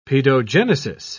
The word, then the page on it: paedogenesis: British English